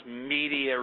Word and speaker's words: Media: Now, my next theory is that in Medea, Pennsylvania, would like to share information with you. Hello. Hi. – I heard somebody else mispronounce media recently. They said on some other station, Medea.